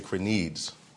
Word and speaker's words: Krenides: This name is spelled K-R-E-N-I-D-E-S. And I talked to Elder McClarty prior to the service, just trying to find out how would you pronounce it. But I say Crenides or Crenides.